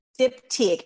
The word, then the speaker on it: Diptyque: I think it's pronounced Diptyque or Duptyque or something like that. – Diptyque.